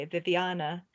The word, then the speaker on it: Viviana: Well, Viviana, is it at or ah when you say your name? Viviana or Viviana? Okay, Viviana.